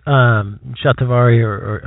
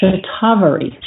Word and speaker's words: shatavari: So this shatavari, how did you pronounce it? – We pronounce it shatavari. – Shatavari. Mm-hmm. – Correct.